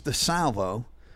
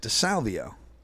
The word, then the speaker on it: DeSalvo: And I go, yeah, his name's DeSalvo. And I pronounce it DeSalvio.